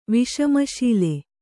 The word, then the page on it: ವಿಷಮಶೀಲ: viṣama sīla